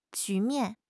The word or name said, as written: 局面